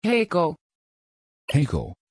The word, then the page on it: heyko: Dutch